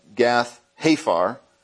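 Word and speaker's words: Gath-hepher: In the section I was reading to you from, he's from a place called Gath, Pathar. I'm not saying that right. Gath Hafar, which is just right next to Nazareth.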